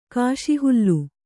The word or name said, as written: ಕುಸಿಹಾಲೆ